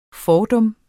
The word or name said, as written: fordum